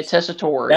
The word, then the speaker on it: Tessitore: Tessitore? – Yeah, Tessitore, yeah.